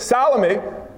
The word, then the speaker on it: Salome: Mary, the mother of the Lord, Mary Magdalene, and the deli lady, Salami. Is that not how you say her name? Well, when you put the accounts together, you see that Salome was a sister of Mary, the mother of Christ.